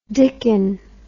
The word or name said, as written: dicken